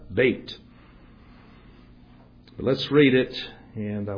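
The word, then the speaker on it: bait (בית): It's pronounced bait, like mate. Even though looking at it, you'd think it was Beth.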